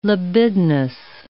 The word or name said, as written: libidinous